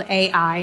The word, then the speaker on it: Ai: Ai is spelled Ai, and you say it Ai, so it's a little confusing.